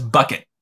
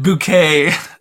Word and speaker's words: Bucket: Okay, so there's a British show called Keeping Up Appearances, and it's this family, this woman, her last name is Bucket, which is like the most, but she pronounces it Bouquet, and so...